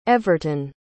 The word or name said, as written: everton